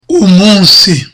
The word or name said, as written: umunsi